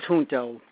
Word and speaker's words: Junto: We say Junto, yes, in New York, but it properly is Junto, because it is a Latin term, and that's the proper expression, proper pronunciation.